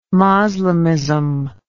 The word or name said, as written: Moslemism